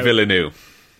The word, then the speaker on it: Villeneuve: I'm going to mispronounce his name, please. – It's Villeneuve.